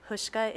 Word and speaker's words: Houska: Christy Hushka. – Christy is spelled? – The last, Christy is K-R-I-S-T-I. – And your last name, please? – Hushka is H-O-U-S-K-A. – Hushka. – Hushka. – Hushka.